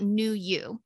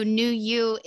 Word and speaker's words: Knew U: And it's spelled K-N-E-W-U, capital U, correct? – Yes, yes, capital U, yes.